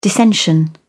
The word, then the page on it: dissension: British English